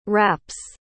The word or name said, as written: wraps